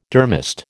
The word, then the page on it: durmast: American English